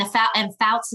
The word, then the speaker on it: Foutz: And Foutz is spelled Foutz.